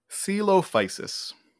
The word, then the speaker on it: Coelophysis: Coelophysis.